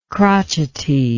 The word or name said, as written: crotchetiness